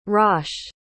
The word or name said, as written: raasch